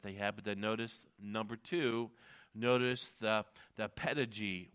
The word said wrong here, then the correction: pedagogy: with the word, the pedigee with the word. It's P-E-D-A-G-O-G-Y, the pedigee, the pedagogy with the word. Sorry, I pronounced it wrong.